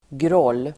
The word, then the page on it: groll: [grål:]